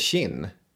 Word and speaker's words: Qin: Visst uttals det Qin? Därav Kina. – Ja, det gör det.